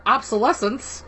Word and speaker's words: obsolescence: marked for obsolescence. Am I saying that right? Because I never use that. Obsolescence. – Yes. Yeah, you're fine.